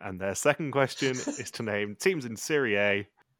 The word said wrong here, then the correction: Serie A: In Serie A, I should say. Do it the proper Italian pronunciation.